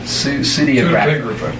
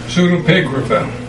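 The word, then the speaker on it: Pseudepigrapha: And that's not including what is referred to, and I don't know if I'll pronounce this right, but the pseudographia. – Pseudopigrapha. – Huh? – Pseudopigrapha. – Him. That's what he said. Pseudopigrapha.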